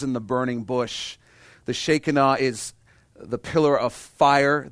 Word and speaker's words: Shekinah: That's right, if you grew up Pentecostal, we've all been saying it wrong. It's not Shekinah, it's pronounced Shekinah.